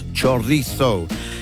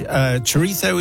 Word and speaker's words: chorizo: And in this case, he's actually told us how to properly pronounce chorizo. Where's Matt when you want him? – He's having a very long holiday. But I always said chorizo and chorizo and so on. But under your tutelage, chorizo is what chorizo.